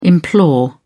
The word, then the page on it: implore: British English